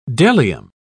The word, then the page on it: bdellium: British English